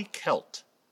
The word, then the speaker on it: Qelt: In modern-day Israel-Palestine, today, just east of Jerusalem, there is a wilderness called the Wadi Kelt. Wadi is the Arabic word for valley. Kelt is spelled Q-E-L-T.